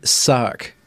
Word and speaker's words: Cutty Sark: I think when you talk about short skirt, talk about a cutty sark. – Cutty sark. – Yeah. – Is that how you say it? Sark? Or sark. S-A-R-K.